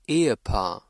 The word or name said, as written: Ehepaar